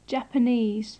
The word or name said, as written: japanese